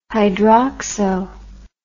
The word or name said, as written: hydroxo